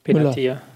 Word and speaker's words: Benatia: Äh, wenn ich jetzt sehe, dass ein Bartstuber wieder fit ist, ein Benati äh, Benatia, Benatia, wie spricht man den aus? – Benatia.